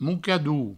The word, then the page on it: mocador: moucadou